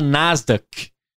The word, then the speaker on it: Nasdaq: Nasdaq. É assim que pronuncia Nasdaq ou não? É isso, é isso.